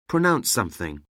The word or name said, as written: pronounce something